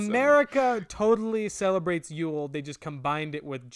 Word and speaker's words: Yul: Yul Brynner is with no E. – That it's Yul? – Yeah, but it's pronounced Yule.